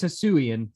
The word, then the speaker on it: Hisuian: Hisuian, I know it's Hisuian for sure, but the region's Hisua or Hisuia.